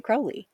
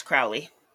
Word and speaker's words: Crowley: Figures like Aleister Crowley, or Crowley, depending on how you pronounce his last name. – Crowley. – And see, some people pronounce it Crowley. – Nope, it's Crowley.